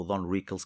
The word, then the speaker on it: Don Rickles: E por isso o Don Wrinkles, ou Don Rickles, que eu tenho a mania de meter um N a mais é, na pronúncia, eh, era um gajo que me enchia as medidas porque ele era assim no dia a dia.